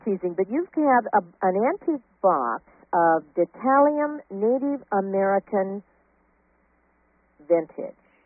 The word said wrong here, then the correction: dentalium: Did I mispronounce that? What that is. – It is dentalium,